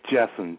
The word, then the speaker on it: Jessen: It's Jessen, J-E-S-S-E-N.